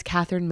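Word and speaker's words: Kathryn: So, you know what's funny is my name is Catherine Mary.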